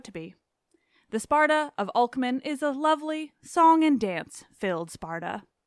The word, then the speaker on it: Alcman: as usual, Greek pronunciation is fake. Yeah. I say Alcman. I think that's fine.